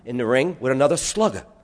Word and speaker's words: slacaire: And there in the dictionary is a word spelled S-L-A-C-A-I-R-E, pronounced slugger.